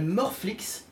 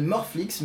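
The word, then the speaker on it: Moreflicks: Euh, euh, donc il y a un, site qui s'appelle Morflix, mais ça s'écrit euh, Mor comme mort en anglais, M-O-R-E. Hmm. Flix, F-L-I-C-K-S.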